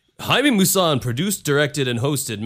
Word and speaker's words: Jaime: And Jaime Musan, which is actually funny enough, we would pronounce his name Jamie. It's J-A-I-M-E, but I guess with Spanish it's pronounced Jaime, whatever.